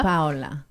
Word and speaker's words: Paola: So we would say hola. – Hola. – Hola. – Paola.